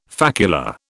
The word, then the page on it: facula: British English